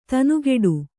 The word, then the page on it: ದನಿಗೊಡು: kannada